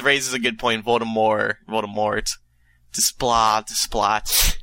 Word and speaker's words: Desplat: And she's writing in to say to us that I did a little bit of research, and if I'm not mistaken, his name is pronounced Desplat, not Desplat. Remember, the T is usually silent in French words when it's at the very end.